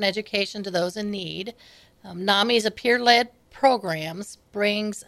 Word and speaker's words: NAMI: NAMI is NAMI, how you say it.